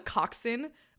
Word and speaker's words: coxswain: It's called a coxswain, or a cox for short, but it's spelled C-O-X-S-W-A-I-N, like coxswain.